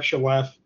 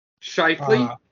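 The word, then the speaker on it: Scheifele: You still have Kyle Connor, Mark – I'm going to butcher the name – Mark Shalef. – Shifley.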